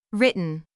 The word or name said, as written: written